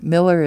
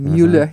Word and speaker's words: Mueller: Sister Joan Mueller, and Mueller is spelled M-U-E-L-L-E-R. I'm from Wisconsin, Mueller, mm-hmm. You know? – Yes. – So it's Mueller, M-U-E-L-L-E-R, and the address is 4801 California Street, number 2, and without the number 2, the post office doesn't want to deliver it.